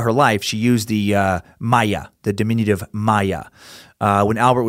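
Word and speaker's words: Maja: To figure out how to pronounce Maya, spelled with a J, M-A-J-A, I ended up finding a video of some strange old German little kids cartoon about a bee.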